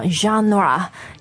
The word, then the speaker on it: genre: Hi guys, today we're talking about music genres. – There are many genres of music. – 对，就是音乐的类型啊。那这里类型我们不用什么 music types or kinds of music. – Right, when we talk about music and movies, we use genre. – Genre, G-E-N-R-E 啊。发音大家听了是好像很浪漫的那个。 – 对，是法语来的。